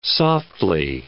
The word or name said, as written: softly